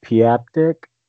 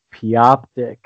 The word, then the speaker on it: Pioptic: I always said Piaptic. Uh, I've said Piaptic for ten years, and then Mike corrected me this summer that it's Pioptic.